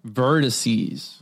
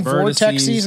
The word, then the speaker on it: vortices: We're going to be doing Strange Wands, the Vile Vortices. – Vortices. I was saying vortexes, and I was like, that's not right.